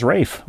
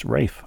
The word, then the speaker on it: Ralph: Ralph Fiennes, the great actor Ralph Fiennes. You enjoyed him in Quiz Show and many other movies. His name is Welsh. Pronounced Rafe, R-A-F-E, but spelled Ralph, R-A-L-P-H.